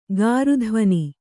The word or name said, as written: ಗಾರುಧ್ವನಿ